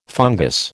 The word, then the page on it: fungous: American English